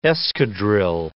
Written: escadrille

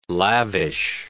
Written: lavish

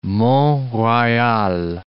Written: Mont-Royal

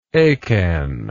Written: achan

